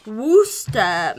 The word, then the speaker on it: Worcester: In Worcester, Massachusetts. – It's Worcester. pronounced Worcester.